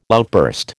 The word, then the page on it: outburst: American English